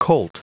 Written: colt

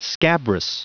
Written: scabrous

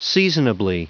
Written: seasonably

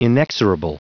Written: inexorable

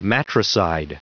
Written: matricide

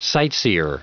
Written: sightseer

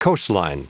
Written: coastline

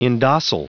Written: indocile

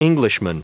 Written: englishman

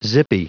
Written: zippy

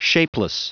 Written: shapeless

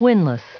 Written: winless

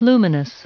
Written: luminous

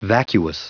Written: vacuous

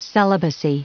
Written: celibacy

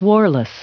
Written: warless

